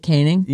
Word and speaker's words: Koenig: They told me I pronounced Sarah Koenig's name wrong. Thank you, 707. – Oh, that's how you say it, Koenig? – Yeah.